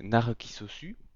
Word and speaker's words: Narcissu: Et parce que de toute façon, la véritable prononciation japonaise c'est Narukisosu.